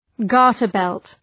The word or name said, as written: garter belt